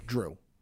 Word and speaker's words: Jrue: Is it Jrue or Drew? – Jrue. – Drew.